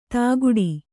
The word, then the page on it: ದುಕ್ಕುಡಿ: dukkuḍi